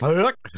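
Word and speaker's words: Huyck: How do you say that name? Is it Huck? – Huck.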